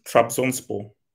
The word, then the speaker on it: Trabzonspor: I think it's Trabzonspor. – You got it, bro.